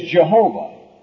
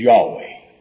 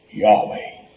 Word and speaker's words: Yahweh: Oh, we hear it falsely spoken of today as Jehovah. But any real brilliant Hebraist, like Dr. Bullinger, or those that have written the Companion Bible, will tell you that the correct name, the correct pronunciation of that name, is Yahweh. Yahweh.